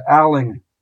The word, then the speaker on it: Alling: I've been called worse, but Ailing. That's what just separates the two L's.